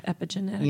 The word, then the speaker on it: epigenetics: and am I saying it right? – Yeah, epigenetics.